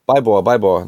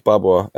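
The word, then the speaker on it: Byborre: Baibor, nicht Barbor.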